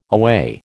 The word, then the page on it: away: American English